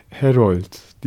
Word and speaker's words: Herold: e, Harald gerçek ismi pardon Herold diye telaffuz edilen bir ismi olan bir psikanalist.